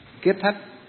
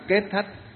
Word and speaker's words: kết thất: Kết thất cũng được mà kiết thất cũng được, vì cùng một cái chữ ấy, nhưng mà miền Bắc thì chúng ta đọc là kết, miền Nam thì đọc là kiết. Cho nên từ kiết thất hay là kết thất đều có nghĩa như nhau, cách phát âm khác nhau thôi.